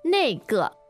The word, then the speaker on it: 那个: 那个。